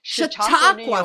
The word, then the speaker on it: Chautauqua: It's hard to pronounce. Chautauqua. – Chautauqua, of course, we're in Indian territory.